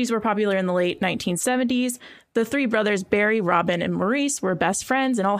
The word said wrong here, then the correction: Maurice: Might be Morris. I think it's spelled Maurice, but it's Morris.